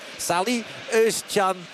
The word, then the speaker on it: Salih Özcan: Ik heb Jordi Amali net gesproken. Die gaf een prachtige voice-app, zoals je mij altijd helpt met het uitspreken van deze namen. En hij zei, let op, het is Salih Özcan.